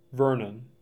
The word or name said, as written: Vernon